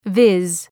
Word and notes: viz: vız

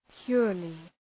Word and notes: purely: ‘pjʋrlı

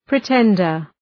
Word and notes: pretender: prı’tendər

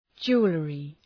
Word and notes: jewellery: ‘dʒu:əlrı

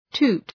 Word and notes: toot: tu:t

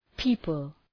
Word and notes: people: ‘pi:pəl

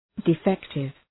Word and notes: defective: dı’fektıv

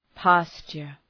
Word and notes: pasture: ‘pæstʃər

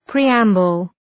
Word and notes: preamble: ‘pri:,æmbəl

